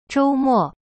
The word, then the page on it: 周末: zhou-mo